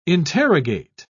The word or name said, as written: interrogate